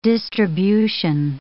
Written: distribution

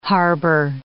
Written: harbor

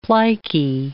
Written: plicae

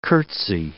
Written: curtsey

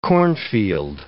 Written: cornfield

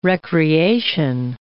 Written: recreation

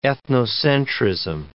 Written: ethnocentrism

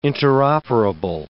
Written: interoperable

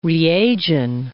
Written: reagin

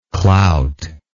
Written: clouter

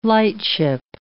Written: lightship

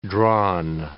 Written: drawn

